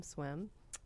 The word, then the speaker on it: Swym: both Bill's project Darksoft and Ryan's project Swim, and that's spelled S-W-Y-M, Swim, in all caps.